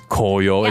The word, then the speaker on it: 紅葉: But the very complicated thing in Japanese, the characters change pronunciation. All you need to know is koyo is how you talk about autumn colors.